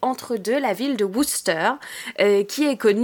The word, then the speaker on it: Worcester: Ah, bien prononcé. Ouais, ouais, ouais. tu bah la prononces très bien. Et oui, on m'a appris là-bas parce que euh, j'ai appris à mes dépens dès le premier jour hein, que ça ne se prononçait pas Worcester,